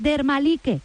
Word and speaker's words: Dermalike: Di que es Dermalike, que es como se escribe, dermalike.es, dermalike.es.